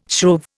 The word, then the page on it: shrove: Indian